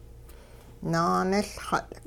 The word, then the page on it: naaniLxut': Hupa